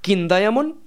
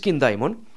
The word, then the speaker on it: King Diamond: Bueno, King Diamond se puede decir de las dos maneras. Es decir, King Diamond, que es la pronunciación, digamos, más british, pero la pronunciación americana, que como pues podéis imaginar, se está imponiendo en casi todo, es King Diamond.